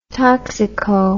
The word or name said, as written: toxico